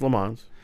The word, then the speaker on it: Le Mans: Is that, am I saying it wrong? It's, well, not Le Mans? – No, it's Le Mans.